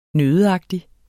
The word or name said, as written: nøddeagtig